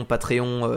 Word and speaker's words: Patreon: Si vous voulez nous soutenir, allez checker sur notre Patreon, Patreon, euh, comme ça se prononce, P-A-T-R. e non, e o n oui, e -O -N.